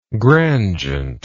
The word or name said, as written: grandgent